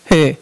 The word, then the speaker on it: Hur: And Moses, Aaron, and Hur, how do you say this? Hur or Ur? Hur.